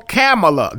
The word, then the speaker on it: Kamala: Just, and hold on, Kamala. That's how you say her name. It's like Pamela with a K.